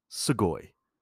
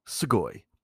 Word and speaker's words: sugoi: That's sugoi. It's spelt S-U-G-O-I, but of course, in Japanese, the letter U is a much softer sound. So instead of something like sugoi, it just comes out as sugoi.